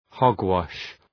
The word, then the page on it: hogwash: ‘hɒg,wɒʃ